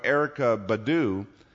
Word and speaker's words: Erykah Badu: Now, that term sort of lied dormant until in 2010, a few years ago, Erykah Badu, did I say that right? Yeah, I said that right, wrote a song called Master Teacher.